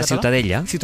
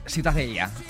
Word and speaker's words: Ciutadella: ¿Tú cómo lo pronuncias? – Ciutadella. – Muy bien.